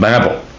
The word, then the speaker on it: Babel: And I know that a lot of people possibly pronounce B-A-B-E-L different ways. I was raised pronouncing it the Tower of Babel.